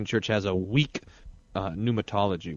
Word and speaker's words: pneumatology: For you, uh, I have the word pneumatology, uh, spelled pneumatology, but it's pronounced pneumatology, which is the study of the Holy Spirit.